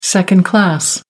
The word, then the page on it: second-class: American English